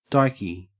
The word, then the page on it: dykey: Australian English